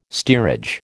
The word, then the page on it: steerage: American English